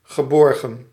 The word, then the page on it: sûr: [syʁ]; France